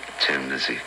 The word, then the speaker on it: Thin Lizzy: And so we made it Thin Lizzy because Dubliners say right. Tin anyway, you know? So this was being smart, you know, the inner meaning, you know, that the pronunciation would be Tin Lizzy and the way it's spelled it would be Thin Lizzy.